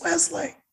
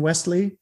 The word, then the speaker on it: Westley: That there was no T in Wesley, even though it is pronounced, the T, I think, is pretty much silent.